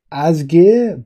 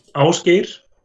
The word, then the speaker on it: Ásgeir: What's the proper way of saying it? – Ásgeir. – Ásgeir. – Yeah, so that's it's a rolling R in the end, and Ál, the first letter.